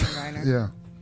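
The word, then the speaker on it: Jeremy Renner: How the hell do you pronounce Jeremy Reiner.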